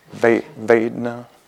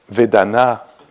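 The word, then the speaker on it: vedanā: Just another little idea around this theme of pleasure, displeasure, neutrality, Vedana, in Pali pronounced in American way. Vedana. Vedana. That's with the French accent.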